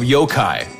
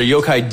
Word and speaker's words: yokai: You pronounced that wrong. – That's it wrong. It's pronounced yokai. Yaki. It's pronounced yaki.